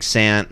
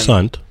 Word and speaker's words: Big Sant: We saw you then, obviously, like, the Crit stuff, like, was really exciting because, you know, him and Big Sant and those guys, like, were doing something. – Sunt. – We're doing something so. – Big Sunt. – Is that the pronunciation? – Yeah, Big Sunt.